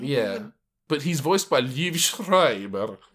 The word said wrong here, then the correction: Liev Schreiber: That's how you say his name. I'm not sure what that was. – It's Liev Schreiber.